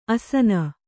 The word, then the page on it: ಆಸೀನ: āsīna